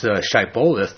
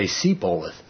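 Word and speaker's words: Shibboleth: And when he says pronounce Shiboleth, they would say Seboleth.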